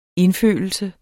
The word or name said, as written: indfølelse